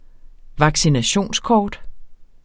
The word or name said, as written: vaccinationskort